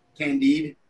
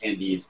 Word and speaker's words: Candide: Candide, C-A-N-D-I-D. – Candid. – Yeah, it's spelled like candid, but it's pronounced Candide. It's French.